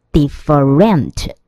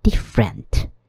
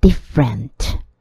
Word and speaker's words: different: 第三个单字是不同的，d i f f e r e n t，很多同学会念成 different 或是 different 都不对哦。它正确的发音中间的那个 e 会被省略，所以 f 跟 r 就直接合起来变成 different。 different。